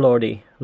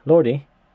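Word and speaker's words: Lorde: And Christy, we're talking about Spotify, and Spotify this week launched a new feature called Spotlight with the artists Haim and Lordi. Lord or Lordi? Lordi? – I say Lord, but I don't know. – I think it's pronounced Lord. – Okay, Lord. Great.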